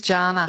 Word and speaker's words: Jana: It is Jana. – It is Jana. – Oh. Yes, I did grow up with Jana Vent on TV, but mine's a hard J, so thanks for checking.